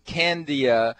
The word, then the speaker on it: Candia: And we're talking with Candia Sanders. And that's how you pronounce her name, C-A-N-D-I-A, Candia.